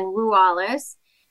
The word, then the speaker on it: Ruales: So Cristina without an H because I'm Latina, and then Ruales, which people often mispronounce rules.